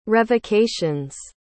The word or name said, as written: revocations